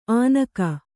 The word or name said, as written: ಆನಕ